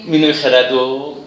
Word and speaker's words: مینوی خرد: خب مینوی خردو آه این من یه تلفظش توضیح بدم این تلفظ غالبا اشتباه میکنن ببینید میگم مینوی خرد یا این پهلویش از مینوگ همون مینو